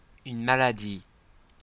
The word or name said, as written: une maladie